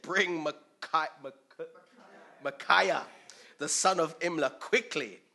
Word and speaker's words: Micaiah: Micaiah, there you go, Micaiah, the son of Imlah, by whom we may inquire of the Lord.